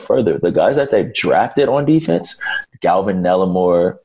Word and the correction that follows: Neville Gallimore: I don't know if I – did I say his name right? – Neville Gallimore. – No, sorry, Neville Gallimore.